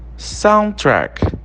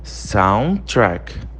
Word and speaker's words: soundtrack: Soundtrack. Soundtrack, tá? Você repara que o D é mudo. Você não fala soundtrack. Você fala soundtrack, tá?